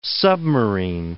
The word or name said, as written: submarine